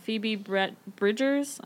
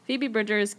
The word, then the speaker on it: Phoebe Bridgers: I, for context, the reason I started listening to this song is I read an article about Phoebe Bridgers. I said that wrong. Phoebe Bridgers getting,